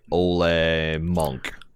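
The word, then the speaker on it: Ole Munch: See, I rolled with this because I'm like, well, Old Munch is this, and I get it. His name is intended to be pronounced like Ole Monk.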